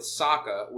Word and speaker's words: Sokka: And there's a lot of development with Sokka, which that's how he's pronounced in the show.